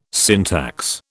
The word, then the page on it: syntax: British English